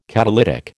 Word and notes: catalytic: American English

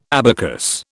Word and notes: abacus: British English

